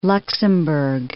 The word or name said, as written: Luxemburg